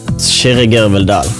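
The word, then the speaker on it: Kyrre Gørvell-Dahll: My real name is pronounced Shirigir Vildal.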